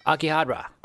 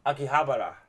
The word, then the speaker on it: Akihabara: Akihabara. – Akihabara.